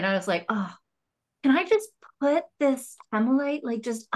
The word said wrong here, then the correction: howlite: under your, it's hemolite, right? – Howlite. – Howlite. I knew I was saying it wrong.